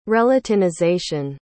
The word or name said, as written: relatinization